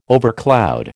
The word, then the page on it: overcloud: American English